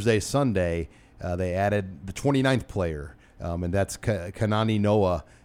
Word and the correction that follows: Kanawai: Did I butcher that, Nate? – Yes, you did. Kanawai Noah.